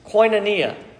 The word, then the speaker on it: koinonia: And the word there, as some of you know it, the Greek word for fellowship or participation is koinonia. Koinonia. So you say it like you've got money, you've got a coin. Koinonia.